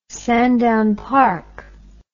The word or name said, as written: sandown park